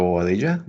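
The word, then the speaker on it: Padilla: And this is Leonardo Oadilla, which rhymes with quesadilla.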